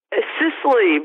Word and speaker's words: Sisley: Is it Sisley or Sisley? – Sisley, but Sisley. That's still good.